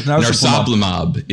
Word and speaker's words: narsoplimab: Like narsoplimab is, I believe, the correct pronunciation of it.